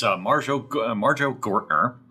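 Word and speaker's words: Marjoe Gortner: How do you say Marger? – It's Marjo Gortner.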